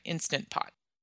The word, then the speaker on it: Instant Pot: So does it even make sense for me to develop an Instapot recipes meal plan if only three of my customers have an Instant Pot. Sorry, I'm not saying that right. Instant Pot.